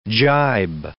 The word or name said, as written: gibe